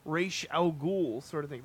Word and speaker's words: Ra's al Ghul: Like, you know, his grandfather, who's Ra's al Ghul. – Yeah, Ra's al Ghul, thank you.